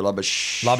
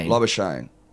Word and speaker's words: Labuschagne: I think I've heard it rhymes with champagne. So Labuschagne. Labuschagne.